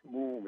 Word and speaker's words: Mumm: Moom Champagne Chef de Cave and Stella Master.